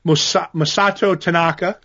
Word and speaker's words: Masato Tanaka: So he fought Masato Tanaka.